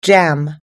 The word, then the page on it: jam: American English